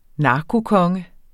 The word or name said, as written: narkokonge